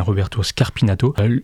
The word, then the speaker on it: Scarpinato: Et ce dont témoigne Roberto Scarpinato, je crois que j'ai écorché son nom en fait en début d'émission, donc c'est bien Roberto Scarpinato, lui...